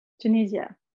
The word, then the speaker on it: Tunisia: So what is the current situation with water, both in Tunisia, or sorry, actually, before I say that, how shall I pronounce the name? Because in the UK, they say Tunisia, in the US, they say Tunisia. – Both works, whatever you feel comfortable pronouncing. – What do you say? – Tunisia. – Tunisia. – Yeah. – Then I'll say that. Okay.